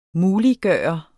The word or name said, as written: MULIGGJORT